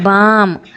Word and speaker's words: balm: GUARD ಗಾರ್ಡ್, BALM ಬಾಮ್, PALM ಪಾಮ್, ಈ ಥರದ ಒಂದಷ್ಟು ಪದ ಹೇಳಿದೆ.